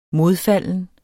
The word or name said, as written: modfalden